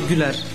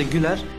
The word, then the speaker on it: Güler: Ama Arda Güler. Normalde Ü ile Arda Güler.